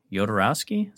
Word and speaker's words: Jodorowsky: Jodorowsky? Is that how you say his name? – Yeah, Alejandro.